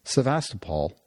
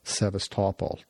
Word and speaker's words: Sevastopol: So they went to work, and in 1784 they developed the new city, which they named Sevastopol or Sevastopol, depending on. But apparently, both pronunciations are acceptable.